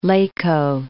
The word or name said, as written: Lecco